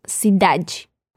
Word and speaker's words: cidade: Lo mismo pasa con la E que no lleva el acento al final de la palabra y la pronunciamos como la I. Luego se puede escuchar como cidade.